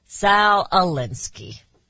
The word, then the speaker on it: Alinsky: Salinsky. Sal Alinsky.